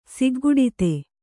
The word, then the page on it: ಸಿಗ್ಗುಡಿತೆ: kannada